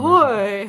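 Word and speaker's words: Ahoy!: And I say it like that because there's an exclamation point. See the exclamation Ahoy.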